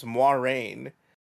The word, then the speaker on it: Moiraine: Like, Moraine isn't Moraine. I think it's in the pronunciation guide, her name is Moiraine, but yeah, that's a Moraine.